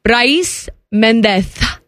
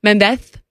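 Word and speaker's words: Brais Méndez: Brais Mendez. That's how you say his name. – Mendez. – Mendez. Like in España. They don't the este. Mendez.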